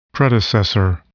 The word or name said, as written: predecessor